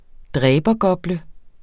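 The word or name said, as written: dræbergople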